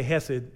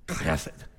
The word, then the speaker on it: hesed: Uh, we say hesed, it's like hesed. You got you gotta hock a loogie to say it right.